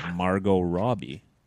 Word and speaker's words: Margot Robbie: It's the first name you've said correct all podcast because it's the easiest name in the world, Margot Robbie.